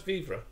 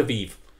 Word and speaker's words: vivre: Is that how it's pronounced? Vivre? – Vivre. It should be without the E,